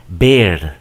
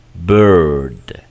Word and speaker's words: bird: ¿Cómo se pronuncia este patrón? De la misma manera. Err, con la E neutral. Escucha y repite conmigo también. Third, thirty, bird. No decimos bear, decimos bird, girl, dirt, firm, girlfriend, first, birthday, circle, shirt, sir.